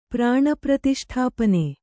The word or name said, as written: ಪ್ರಾಣಪ್ರತಿಷ್ಠಾಪನೆ